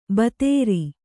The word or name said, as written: ಭದ್ರೆ